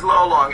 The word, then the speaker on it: Lolong: His name is Lolong, L-O-L-O-N-G.